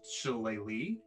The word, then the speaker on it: shillelagh: And I'm gonna cast this cantrip, Shilela. I think it's what it says. – Shilela. – For the record, Drew, it's pronounced Shileli, but that looks nothing like what it's pronounced.